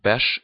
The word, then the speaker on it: bärs: But as you can hear, the RS, like bärs, is pronounced B-A-R-S. And it becomes like some sort of CH sound.